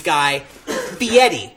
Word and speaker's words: Fieri: Guy Fieri.